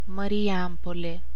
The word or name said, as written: Marijampolė